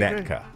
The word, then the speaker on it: Winnetka: Now, my Fire Knot Restaurant of the day today, Old Hood of Gregory, would be in Winnica, Johnny Pacific, Winnica. Latin America Empanadas. Winnica. – Ooh. Winnica. – Yes. Did I not say it right? – Winnetka.